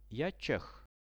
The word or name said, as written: ja cheh